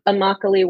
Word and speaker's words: Immokalee: And how do you pronounce that? Is it Immokalee? – Coalition of Immokalee Workers.